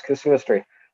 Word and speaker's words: casuistry: Is that how you say it? – Yes, casuistry.